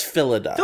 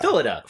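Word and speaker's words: Phyllida: Her mom is Philidia. Is that how you say it? – I think it's Philida. – Philida.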